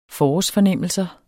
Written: forårsfornemmelser

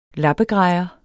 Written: lappegrejer

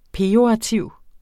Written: pejorativ

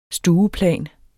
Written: stueplan

